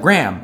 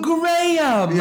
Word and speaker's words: Graham: And I had explained two times, and then they went, oh, Graham, yeah. Okay. And I was like, yeah, I've butchered my own name.